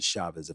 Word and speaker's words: Chavis: Spelled Chavis, but they said the pronunciation is Chavez.